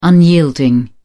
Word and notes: unyielding: British English

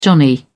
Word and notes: johnny: British English

